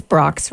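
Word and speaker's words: Brach's: Because I discovered that Brock's, B-R-A-C-H-S, you know, the candy maker? – Oh, yeah, definitely, It's Brock's, right? – It's Brock's. – That's how you say it. – That's the way I say it.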